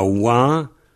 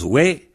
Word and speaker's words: wa: À l'imparfait, on a Wa, qui se prononce We, mais on a Wa pour le verbe être.